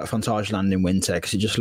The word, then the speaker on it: Fantasialand: But yeah, we just always combine it with going to whatever theme park is open over the winter period, and Fantasialand do the winter trim. Is that how you say it? – Yeah, I think so. Yeah, that's the way I say it.